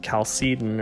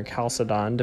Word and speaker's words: Chalcedon: It's interesting, classical Christian theology and the Council of Chalcedon or Chalcedon, depending on how you want to pronounce that word, held that Jesus was fully God and fully human.